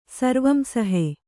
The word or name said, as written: ಸರ್ವಂಸಹೆ